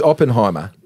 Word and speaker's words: Oppenheimer: Oppenheimer? – Yeah, that's right.